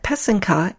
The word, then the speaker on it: pysanka: The word is spelled P-Y-S-A-N-K-A. The word pesenke is the plural. It's similarly spelled, but with a Y at the end instead of an A. So it's spelled P-Y-S-A-N-K-Y. The accent is on the second syllable. An easy way to remember how to say it is that the first and third syllable rhyme. So that's the pe and the ke. Put it all together and you get pesenke.